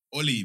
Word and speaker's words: Ole: As someone who hates on Ole, I hate the way you say his name, Ole. Oli, man.